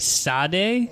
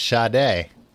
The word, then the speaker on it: Sade: Yeah, and why is it Sade? I would understand if it was Sade. – Right. But it's not, it's Sade.